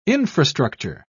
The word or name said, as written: infrastructure